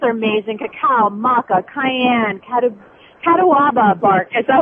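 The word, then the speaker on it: catuaba: Catawaba. I think. – it's pronounced several different ways, but catawaba is how we're going.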